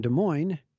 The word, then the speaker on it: Des Moines: So I was heading from Des Moines, don't pronounce the S's,